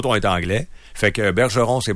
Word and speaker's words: Bergeron: Normalement, il écrit ça. Bergeron. – C'est ça, tu ne prononces pas le N, juste on. Ils ne sont pas capables.